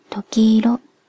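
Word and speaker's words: 朱鷺色: 淡く優しい桃色、朱色の朱に鳥のさぎという字に色と書いて時色と読みます。特別天然記念物の時、時の風切り羽の色から名付けられた色名です。時の羽の裏側はほんのり桃色に染まっていて、空を舞う時色は天の羽衣のようかもしれません。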